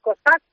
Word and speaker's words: Kozak: Es como proviene de cosacos. Mi apellido es de cosacos en Ucrania, ¿no? Es Kozak.